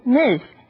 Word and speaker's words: Ms.: 用的 Ms. 它的发音是 Ms. S 发的是 Z 的音，念成 Ms. 它是女士的意思。通常在不清楚对方已婚或是未婚，就是女士 Ms. 来称呼对方。一般在职场或正式场合，就算你知道对方未婚，也会用 Ms.那这是一种专业和礼貌的用法。第二种是 Miss 小姐，用来称呼未婚的小姐，它的发音是 Miss， S 是发 S 的音，念成 Miss。